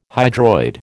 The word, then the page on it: hydroid: American English